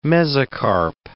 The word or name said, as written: mesocarp